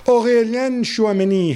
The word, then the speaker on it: Aurélien Tchouaméni: Aurélien Tchouaméni has... My pronunciation okay? – Yeah, very good.